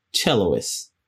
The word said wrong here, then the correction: cellist: I said it right? – Yeah. – Like, no, sorry, go ahead. – Sure, cellist, it doesn't matter.